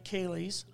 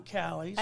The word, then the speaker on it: Caley's: And it's spelled C-A-L-E-Y-S. – Yeah, it's definitely spelled like Kaylee's, I found out, but it's pronounced Kelly's.